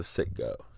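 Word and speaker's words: Citgo: Yeah, it's like under the Sitco sign, the Sitgo sign.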